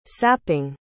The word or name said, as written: sapping